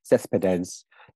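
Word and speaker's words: Céspedes: I'm going to pronounce it very badly because she was Italo-Cuban, so it's probably have to do it with the Cuban accent, but it's Cespedes is the name.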